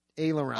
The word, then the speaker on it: aileron: Aileron.